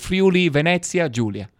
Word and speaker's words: Friuli Venezia Giulia: Far, far to the northeast, we find this sort of square-shaped region called Friuli Venezia Giulia. – Okay. I love having the official pronunciation of things.